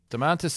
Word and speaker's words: Domantas: Domantas Sabonis, Indiana Pacers.